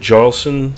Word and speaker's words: Jarlson: But the whole Sigurd Jarlson. – J's are pronounced like Y's.